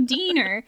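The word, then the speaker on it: diener: Dr. Hines's deaner, it's D-I-E-N-E-R, and I know I pronounced it correctly because I looked at the pronunciation and put a note that says, it's pronounced like wiener, but with a D. So deaner. And a deaner is basically just a fancy word for assistant.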